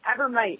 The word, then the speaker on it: Abromeit: Abromeit, it's like dynamite.